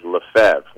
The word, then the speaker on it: Lefebvre: But I'm surprised at how many people will spell it L-E-B. – Yes. – And come up with left. For Lefevre. – Uh-huh. – Now, the correct French pronunciation is Lefebvre, which doesn't make any sense to me at all because you're leaving out about 9 letters when you say it that way.